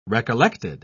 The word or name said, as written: recollected